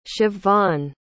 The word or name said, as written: siobbhan